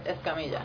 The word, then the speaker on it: Escamilla: My name is Amanda Escamilla. My last name is spelled E-S-C-A-M-I-L-L-A. – Is it Escamilla or Escamilla? – Escamilla.